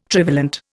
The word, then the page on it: trivalent: Indian